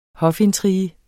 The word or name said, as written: hofintrige